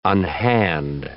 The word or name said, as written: unhand